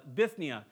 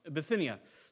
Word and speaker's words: Bithynia: So they take this monster right turn, and they're going to go into Bithynia. I always pronounce that wrong. Bithynia.